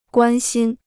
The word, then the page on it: 关心: guān xīn